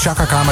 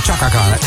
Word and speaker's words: Chaka Khan: Als je twijfelt, hoe spreek je het ook alweer uit? Oh. Dat je niet zegt Chaka Khan, maar Chaka Khan.